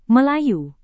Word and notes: melayu: Indonesian

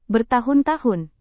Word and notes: bertahun-tahun: Indonesian

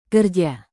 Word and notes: kerja: Indonesian

